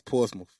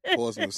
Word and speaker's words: Portsmouth: So how do you properly say, I don't even want to say it. Go ahead and say it. – It's Portsmouth. Yeah. Portsmouth. Sound like you're saying it with an F instead of a TH.